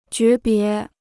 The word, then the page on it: 诀别: jué bié